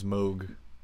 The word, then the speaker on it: Moog: The last Moog. Part is Moog.